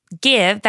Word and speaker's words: GXVE: Okay, so Gwen Stefani uh, this week has launched her own uh, makeup line, and it's called give. That's how it's pronounced. It's mm. G X V E.